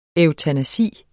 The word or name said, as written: eutanasi